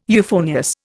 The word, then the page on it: euphonious: Indian